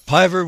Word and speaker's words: Piver: Yeah, Piver with a P rhymes with diver, and he's the guy who popularized the modern trimaran.